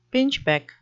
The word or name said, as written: pinchbeck